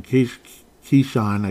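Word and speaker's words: Keisean: But I guess it didn't matter because they had somebody named Nixon, Keyshawn, I guess it's pronounced, even though it doesn't, it's spelled differently, but you can tell, you can pronounce it the same. Keyshawn Nixon, 105 kick return.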